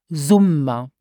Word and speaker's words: Sommer: So to say the spring is Trejoa, the summer is the Sommer, and pronounce it Sommer, the Sommer.